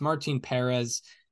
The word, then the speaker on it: Martin Perez: It's Martin Perez. The accent is on the first E, not the second E.